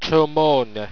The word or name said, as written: toemorgne